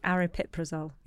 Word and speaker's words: Aripiprazole: Aripiprazole. – Aripiprazole. – You see, it says it much better than me.